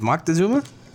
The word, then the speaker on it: Moctezuma: I can tell you that Montezuma. I think it's Moctezuma.